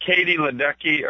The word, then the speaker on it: Katie Ledecky: But Katie Ledecky, or I think I'm pronouncing her name right. – Yeah, that's her. Yep.